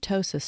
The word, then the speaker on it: ptosis: By the way, that can be purely from the extra skin, or occasionally there is an additional phenomenon called ptosis playing a part. The word ptosis starts with a silent P, and it describes a problem with the actual lifting mechanics of the eyelid.